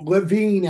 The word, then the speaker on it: Levine: Levine, actually.